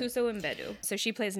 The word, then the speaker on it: Mbedu: There's Viola Davis as Naniska, and then there's Nawi is played by Tuso Mbedu. – So they pronounce the M, okay.